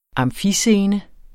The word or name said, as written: amfiscene